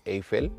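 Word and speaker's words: Eifel: de hecho durante el Gran Premio de Eiffel, que es la pronunciación correcta,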